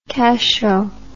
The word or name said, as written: cachot